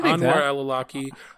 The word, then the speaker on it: Anwar al-Awlaki: And even in that same year, in September of 2011, he had another major victory with taking out Anwar al Al-Awlaki, I can't even say his name, Anwar al-Awlaki, basically that was like the second in charge of Al-Qaeda under homeboy Osama bin Laden.